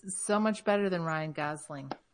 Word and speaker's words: Gosling: He's a Gosling, not a Gosselin.